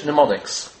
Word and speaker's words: mnemonics: Fancy English, mnemonics. Yeah? That would be good for Rabbi Belovsky, yeah? Mnemonics. Spelt with an M-N at the beginning. Silent M.